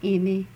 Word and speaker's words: Emmie: Emmy L. – How do you spell that, Emmy? – E-M-M-I-E.